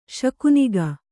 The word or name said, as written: ಶಕುನಿಗ